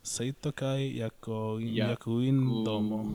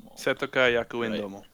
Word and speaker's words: Seitokai Yakuin Domo: Seitoka Yakuin Domo, if I'm pronouncing that correctly. An anime that S-Y-D. – I finally figured out. Seitokai Yakuin Domo. – Seitokai Yakuin Domo.